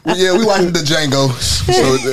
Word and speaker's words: Django: The J is silent. – It's Django. – Yeah. – She said, but that's, I know, mm-hmm. But the D is silent. How can you not hear a D? – The it's Django, like, baby.